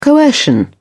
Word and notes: coercion: British English

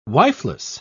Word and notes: wifeless: British English

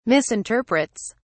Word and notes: misinterprets: British English

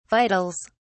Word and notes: vitals: British English

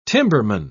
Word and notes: timberman: British English

